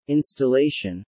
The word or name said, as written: installation